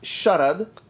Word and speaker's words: Sharad: No, so I say Sherrod. I've said Sherrod since kindergarten because that's what other kids say. And they must be right. So, but my name is actually pronounced Sherrod.